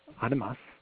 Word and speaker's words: animas: Animus, by the way, this is the Spanish word, or better pronounced, animas. It's A-N-I-M-A-S, and it's the Spanish word for souls.